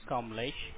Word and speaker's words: Kamlesh: My name is Kamlesh, and I called for the member at this hotel, at this hotel, be like Bravo, be like Papa, 10092.